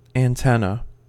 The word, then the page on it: antenna: American English